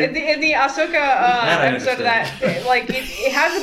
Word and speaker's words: Ahsoka: Watching some of the animated stuff, they do that, like in the Asuka episode of that Jedi Tales. They Ahsoka.